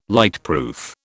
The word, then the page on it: lightproof: British English